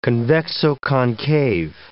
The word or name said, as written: convexo-concave